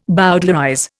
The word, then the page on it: bowdlerize: Indian